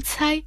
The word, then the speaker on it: 猜: «Цай» в первом тоне означает подозрение, недоверие, сомнение.